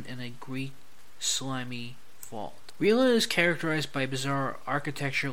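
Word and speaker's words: R'lyeh: I want to make sure I pronounce it Rilila.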